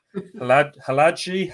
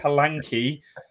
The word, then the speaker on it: Hladký: You've added a G in. Ross has got an N, stuck an N in there somewhere. We've got Hladký, Hladký.